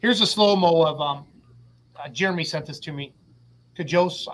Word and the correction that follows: Kjose: I don't know if I'm saying his name right. K-J-O-S-E. Is that silent? – Chose. – Chose. Thank you, Roos.